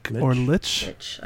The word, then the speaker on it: lich: Shouldn't that have a T in it if you're going to pronounce it lich? Nope. Like witch?